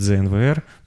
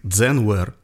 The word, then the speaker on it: ZNWR: Вообще часто люди произносят «знвр». Типа «знвр» — это вот ну, «знвр» а, читается как «дзенвер».